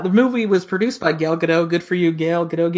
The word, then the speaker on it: Gal Gadot: I think it's Gal Gadot. – Gal Gadot. Yeah, it's Gadot. It's kind of to what we would pronounce it. I researched it.